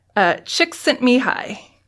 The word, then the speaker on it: Csikszentmihalyi: His name is Mihai, that's his first name, Csikszentmihalyi.